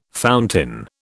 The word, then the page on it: fountain: British English